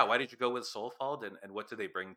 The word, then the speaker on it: Solefald: This next one is the band Solifold. Am I saying that right? – Yep.